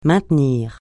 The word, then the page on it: maintenir: francais